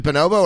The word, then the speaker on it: bonobo: Is it bonobo or bonobo? – It's bonobo, but also I feel like people don't really care about pronunciation that much. Like I hear people using a bunch of different pronunciations for macaque. We usually just say bonobo.